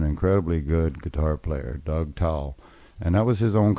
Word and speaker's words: Towle: Now, his name is pronounced like Towle, that you dry yourself off with, but it's spelled T-O-W-L-E, if you want to Google this guy up.